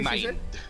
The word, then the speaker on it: Mind: Mine is mind. – Mind. Mind. – M-I-N-D.